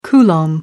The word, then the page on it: coulomb: American English